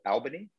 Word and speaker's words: Albany: Do you know how we pronounce that? – How? Not the same way. – We pronounce it different. How do we pronounce it? Guess. Try it. – Albany? – Perfect. There it is.